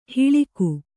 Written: ಹಳಗ

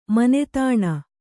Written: ಮಾನಧನ

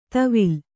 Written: ತವಿಲ್

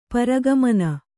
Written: ಪರಗಮನ

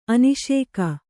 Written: ಅನಿಸಿಕೆ

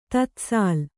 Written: ತತ್ಸಾಲ್

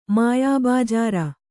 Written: ಮಾಯಾಬಜಾರ

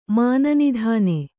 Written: ಮಾನನಿಧಾನೆ

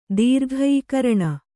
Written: ದೀರ್ಘೀಕರಣ

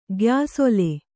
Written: ಗ್ಯಾಸೊಲೆ

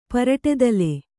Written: ಪರಟತಲೆ

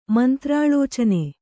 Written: ಮಂತ್ರಾಳೋಚನೆ

